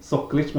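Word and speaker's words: Soklič: En artist som heter Anna Skolic. – Mm. – Soklic menar jag. Soklic. Vill jag uttala det som, men ja, Anna.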